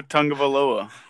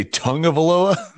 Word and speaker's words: Tagovailoa: It's Tua Tagovailoa. – You say Tagovailoa? – Yeah. – Tua. Oh, it was Taga. – Tagovailoa.